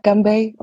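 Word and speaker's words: Ganbei (干杯): Das heißt einfach Ganbei und ich habe es mir für immer gemerkt. Stimmt das schon, ne? – Ja, perfekt. – Ausgesprochen. Ja, Ganbei.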